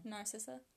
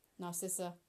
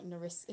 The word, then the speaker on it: Narcissa: Narcissa. – Narcissa. It's like this whole debate me and my brother used to have on, is it like Nariska? He always used to say Nariska.